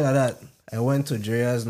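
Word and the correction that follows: Drai's: nightclub. – Dre's. – Yeah. I say it wrong. Say that again. – Dre's.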